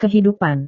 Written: kehidupan

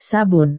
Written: sabun

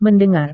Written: mendengar

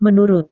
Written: menurut

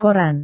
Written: koran

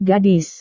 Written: gadis

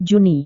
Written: juni

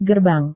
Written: gerbang

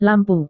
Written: lampu

